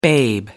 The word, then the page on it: babe: American English